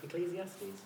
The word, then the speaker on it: Ecclesiastes: Ecclesiastes.